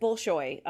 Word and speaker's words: Bolshoi: And we had my teacher at the time was I'm going to butcher it, Bolshoi.